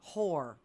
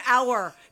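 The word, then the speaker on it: hour: Whore. I was like, it's not whore. It's our. The H is silent.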